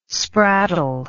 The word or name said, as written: spraddle